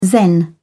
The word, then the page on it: zen: British English